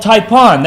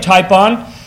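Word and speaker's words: typon (τύπον): The word type means, it comes from a Greek word called typon. That's how it's pronounced anyway, typon.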